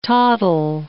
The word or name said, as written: toddle